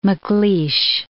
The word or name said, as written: MacLeish